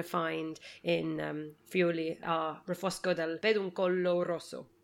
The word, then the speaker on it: Refosco del Peduncolo Rosso: But actually, specifically, Refosco del Peduncolo Rosso. Am I pronouncing that right? Was that a terrible attempt? – No, absolutely. It's perfect.